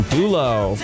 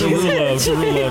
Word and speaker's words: Derulo: Right in solo, Jason Derulo. Did I say his name? Yes. Derulo.